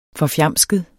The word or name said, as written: FORFJAMSKEDE